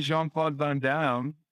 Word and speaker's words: Jean-Claude Van Damme: Yeah, him and Jean-Claude Van Damme.